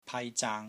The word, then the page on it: PAI1 ZAANG1: Cantonese